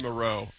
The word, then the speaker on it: Moreau: Yeah, Fabian Moreau is the correct way.